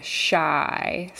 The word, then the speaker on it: Chi: But the baby is going to go by the nickname Shy, spelled C-H-I, but pronounced Shy, which I can get on board with Shy.